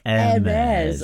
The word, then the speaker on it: Hermes: Yeah, he says, oh, come on, guys, there's not a standard delivery company, Hermes. Hermes. – Hermes. Oh, you've got to pronounce it properly, fair enough, Mr. Clark.